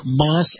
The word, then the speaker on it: Maus: And that's going to include Moss Acura and Moss Nissan. Now, Moss is spelled M-A-U-S. That's M-A-U-S.